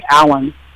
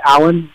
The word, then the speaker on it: Allan: So if you'd like to go deeper with me, I have a free YouTube channel called Meditate with Alan. So my name is spelled A-L-L-A-N. Again, when you do a search, Meditate with Alan, make sure there's no spaces in between.